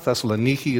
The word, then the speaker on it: Thessaloniki: Thessaloniki is how they pronounce it in Greek.